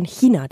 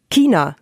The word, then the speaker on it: China: Es ist durchaus korrekt, wenn wir von China die ganze Zeit sprechen. Nicht China, wie immer noch viele Menschen sagen. – Es heißt China.